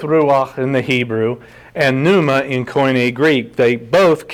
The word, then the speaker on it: pneuma: And the Koine Greek word, which I believe he most certainly used or brings up in his conversation, is pneuma, P-N-E-U-M-A. The debate is the Greeks may have actually pronounced the P, pneuma. We don't. We pronounce it pneuma.